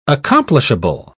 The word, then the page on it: accomplishable: British English